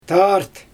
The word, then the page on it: taart: Xyzyl